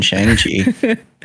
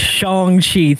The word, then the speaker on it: Shang-Chi: The old wrong pronunciation, Shang-Chi. Oh, man. – It's Shang-Chi. Thank you, sir.